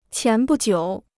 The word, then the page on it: 前不久: qián bù jiǔ